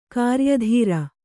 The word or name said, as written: ಕ್ರಯದಾರ